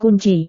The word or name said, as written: kunci